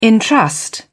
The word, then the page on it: entrust: British English